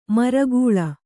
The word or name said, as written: ಮರಗಾಳಿ